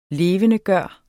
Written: LEVENDEGJORTE

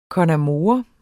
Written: con amore

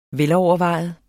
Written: velovervejet

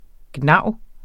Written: gnav

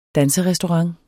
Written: danserestaurant

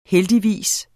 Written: heldigvis

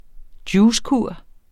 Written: juicekur

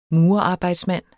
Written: Murerarbejdsmand

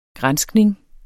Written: granskning

